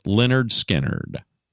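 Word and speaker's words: Lynyrd Skynyrd: Well, they hit big time about the time school started back in August of 1973 with their album, pronounced Leonard Skinnerd.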